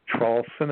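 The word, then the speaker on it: Traulsen: My name is Hugh, H-U-G-H. The last name, Trawlson, is spelled T like Tom, then T-R-A-U-L-S like Sam, E like Edward, N like Nancy.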